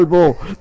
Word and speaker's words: bo: Bo. B-O. – Bo. Un pralbo. bo. Ajá. ¿Bo o bu, maestro? Bo, bo, B-O nada más.